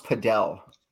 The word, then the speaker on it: padel: So exactly, I think it's actually pronounced padel.